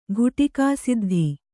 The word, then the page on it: ಘುಟಿಕಾಸಿದ್ಧ: ghuṭikāsiddha